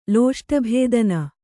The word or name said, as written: ಲೋಷ್ಟಭೇದನ